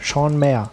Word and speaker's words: Maher: Ich war nämlich letztens auf der FedCon 10 und da war auch Sean Maher. Heißt der so? Echt? Mayer. Sean Mayer. Genau, man sollte den Namen englisch aussprechen.